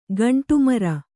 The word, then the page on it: ಗಂಡುಮೀರು: gaṇḍu mīru